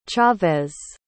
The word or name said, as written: chavez